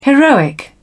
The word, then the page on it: heroic: British English